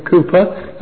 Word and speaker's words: Cowper: I'm reminded of the hymn of William Cowper, or William Cooper, as some pronounce it, on prayer.